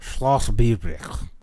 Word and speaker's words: Schloss Biebrich: And the second word is B-I-E-B-R-I-C-H. – Biebrich. – Biebrich. – Schloss Biebrich.